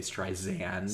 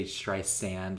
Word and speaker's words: Streisand: Because I just pronounced her name correctly, and she's really big about making sure you don't say Streisand. – Streisand. – You say Streisand, like sand on the beach, sand.